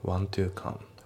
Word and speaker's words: want to: で、発音ですけど、 want の t が抜けますね。 want to come。 want to じゃなくて want to だね。